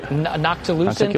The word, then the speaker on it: noctilucent: How do you say that? Nonticulant cloud, right? – Noctilucent. – Nonticulant cloud? Noctilucent. Yeah, yeah. I've mispronounced it like a thousand times.